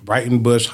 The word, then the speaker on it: Breitenbush: B-R-E-I-T-E-N-B-U-S-H. – Okay. – It's not, it sounds like bright, but it's B-R-E-I-T-E-N. – Okay. – Brighton Bush Hot Springs Retreat Center.